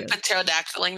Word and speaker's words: pterodactyling: Are we pterodactyling now? – Yeah. yeah. – I like I, I like to pronounce the T in pterodactyl.